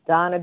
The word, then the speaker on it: Dana: My name is spelled D-A-N-A, but pronounced Donna.